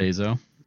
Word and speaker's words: Bezos: Bezos.